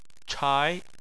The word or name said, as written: chai2